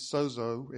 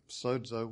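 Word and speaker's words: sozo (σῴζω): He came to us as what the Bible said, a Savior, which if you take that to the Greek in its derivation, the word is sozo, as we would pronounce it in English, or sozo, which is probably more accurate, which means much, much more than just a ticket to heaven.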